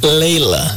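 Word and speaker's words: Layla: Pero se escribe Lila, pero sí lo pronuncia como un poquito Laila, ¿no? – Sí, es que va con Y. Laila. – Exacto. – Es Laila y el de Eric Clapton es Leila.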